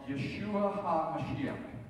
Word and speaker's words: Yeshua HaMashiach: We should all be praying that they come to a place of knowledge of Yeshua HaMashiach. You know what that is? Yeshua HaMashiach. Jesus the Messiah. That's how you say it in Hebrew.